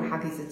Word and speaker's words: haggis: 彼此不吃的东西，包括苏格兰吃 haggis，就是这个 haggis 的原原来的发音就是 haggis，其实就是把一些嗯羊肠啊、羊肺啊剁碎了以后跟嗯燕麦混合在一起蒸的一个血肠，就这个东西很多人也是不吃的。